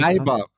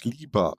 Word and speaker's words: Leiber: Leiber. Le Leiber ist wirklich der, die richtige Aussprache. Die Engländer sagen nur immer falsch Lieber. Nein, das ist wirklich kein Witz. Auf der amerikanischen Wikipedia-Seite ist die richtige Aussprache lautsprachlich wiedergegeben, Leiber, aber Amis sagen immer Lieber ist interessant, weil in dem Vorwort von Der unheilige Gral sagt der Michael Moorcock ausdrücklich, dass es lieber ausgesprochen wird.